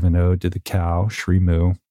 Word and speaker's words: Srimu: Is that how you say it? – So Julie recently launched a plant-based cheese company called Srimu, S-R-I-M-U.